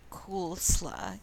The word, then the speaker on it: koolsla: What was the original word? – Coolsla, K-O-O-L-S-L-A, which was a shortening of cool salad, which is K-O-O-L-S-A-L-A-D-E.